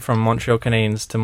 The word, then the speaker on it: Canadiens: Because they're not the Canadians. They're the Canadiens. Right? Because that's how you say it.